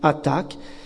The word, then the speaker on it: Hathac: Ça s'écrit H-A-T-H-A-C, Attac, et ça veut dire vérité.